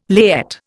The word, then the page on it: layette: Indian